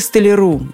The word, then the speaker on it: столяр: Столяр, столяра, столяру, столяром, а столяре. Ударение повсеместно на конце слова. Точно так же во множественном числе.